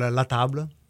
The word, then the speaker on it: La Table: How often do people call it La Table? – 99% of the but it's called La Table. – Yeah. La Table? – La Table. – So I've been mispronouncing it the whole show.